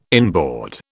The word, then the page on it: inboard: British English